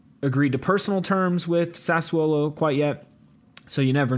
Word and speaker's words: Sassuolo: Sassuolo.